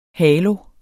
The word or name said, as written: halo